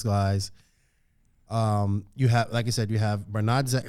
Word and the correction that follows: Bernadzik: Am I saying his name right? Bernard Zach? – Banasik, yeah. – Bernadzik, yeah, who I got introduced to last year.